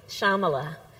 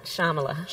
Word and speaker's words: Shyamala: Shyamala. So the Y is silent. Shyamala. – Shyamala.